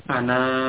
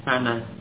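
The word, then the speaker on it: ana (أنا): Sedikit faedah untuk Bomir mutakalim ya ana ya ini membacanya pendek ya ana jadi na di sini nun yang di fathah ini dibaca pendek alif yang sesudahnya itu tidak dibaca ya alif yang ada di akhir pada bomir ana ini tidak dibaca berarti apa membacanya pendek bukan ana bukan seperti itu tapi ana.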